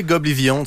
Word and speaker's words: Goblivion: Goblivion. C'est comme ça que je prononce ça? – Tu peux dire Goblivion, tu peux dire Goblivion.